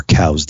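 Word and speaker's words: Coues: It's much harder to get a mule deer tag rifle here than it is a coos deer tag, or cow's deer is actually the correct pronunciation for it, but anybody who's from Arizona calls them coos.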